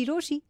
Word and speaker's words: Rochie: मेरा नाम है मिर्ची रोशी, नाम की स्पेलिंग है R-O-C-H-I-E.